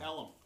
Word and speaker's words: Ellum: Elam or Elm? – Elm. – Elm. 5 miles of Elm Wood. – E-L-L-U-M. – E-L-L-U-M. That's exactly how it's spelled.